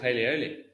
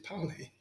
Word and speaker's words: Paoli: It's called Paleoli. – No, it's Paoli, surely. – Paleoli. I've just been saying it wrong my whole life. – It's like Carl Paoli.